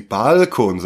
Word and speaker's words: Balkon: Also wir kennen ja alle diese Schweizer Variante, dass sie Balkon sagen, also immer auf die erste Silbe gehen.